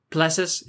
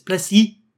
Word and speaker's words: Plessis: You don't you don't pronounce his name Plessis. It's Plessis. Dricus du Plessis.